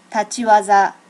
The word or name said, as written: tachi waza